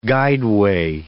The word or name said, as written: guideway